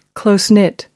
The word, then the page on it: close-knit: American English